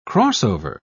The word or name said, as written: crossover